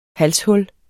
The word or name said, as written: halshul